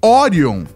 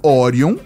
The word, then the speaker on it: Orion: Na verdade, Orion é a pronúncia em inglês de Orion. E o veículo de equipe multifuncional Orion,